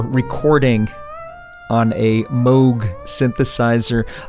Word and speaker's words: Moog: I pronounce it Moog because Wendy Carlos pronounces it Moog. Up until recently, I've been pronouncing it Moog. So it depends on my mood. It depends on my Moog, how I pronounce it.